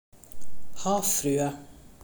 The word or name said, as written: havfrue